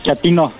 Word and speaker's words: chatino: Chatino. ¿Chapino? No, chatino. – Es como chato, ¿verdad? Chatino. – Sí, primero como chato, chatino.